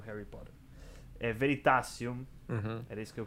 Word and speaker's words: Veritasium: Veritaserum. – Non so come pronunciare. È americano, tanto Veritaserum. – Veritasium.